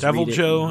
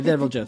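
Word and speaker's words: Deviljho: Odogoron and Devilho with a J in there. I don't know how to pronounce it. I just Devil Joe, I think. – Yeah, Devil Joe. Thank you.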